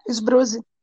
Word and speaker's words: Sbruzi: com o seu sobrenome, é Sbruzi que fala? É, Sbruzi. Ah, então eu sempre falei certo, pô.